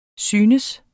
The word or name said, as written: synes